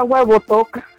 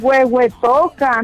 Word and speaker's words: Huehuetoca: Yo con perdón me decía, yo decía huevo toca. Era así, no me entra. Pues, ¿cómo es huehuetoca? No huevo toca.